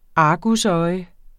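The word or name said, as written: argusøjne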